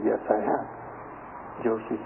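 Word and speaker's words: Jōshū: One of the great Chinese Zen masters about 1,200 years ago, in Japanese the name is pronounced Joshu.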